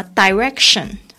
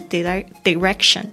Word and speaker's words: direction: 好的， direction 这边有两个发音，大家稍微注意一下，这个发 direction 或者是 direction 都可以的。